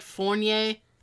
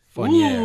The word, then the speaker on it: Fournier: His first name is Vincent, and I can never pronounce his last name correctly. It's like Fournier. – Yep. Hey! Vincent Damon Fournier.